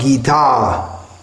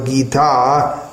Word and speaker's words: Gita: Not gita. Bhagavad Gita we have, but here it's not Gita, it's Gita with a short A after the T.